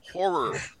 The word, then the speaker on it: horror: In my mind, but yes, you gotta use that second horror. Syllable. Horror. The second R is important there.